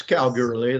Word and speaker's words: Kalgoorlie: That's Kalgoorlie, that's it.